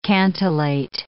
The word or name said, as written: cantillate